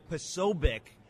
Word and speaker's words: Posobiec: It's Posobiec. We'll be right back. Folks, it's the Eric Dax Show. I continue my conversation with Jack Posobiec. That's how he pronounces it, and he should know.